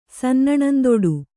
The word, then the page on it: ಸನ್ನಣಂದುಡು: Kannada